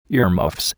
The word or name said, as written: earmuffs